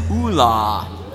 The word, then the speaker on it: Oola: The official pronunciation for this character is Oola. – Oola!